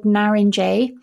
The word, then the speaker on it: Narince: On the kind of fruitier side, you have a variety called Naringe, which is spelled N-A-R-I-N-C-E, Naringe.